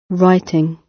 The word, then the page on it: writing: British English